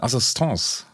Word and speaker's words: Assistance: Jetzt haben Sie in Ihrer Studie festgestellt, und äh, ich muss zugeben, ich habe es immer falsch ausgesprochen, äh, Assistance, sagen Sie, ne? Mhm.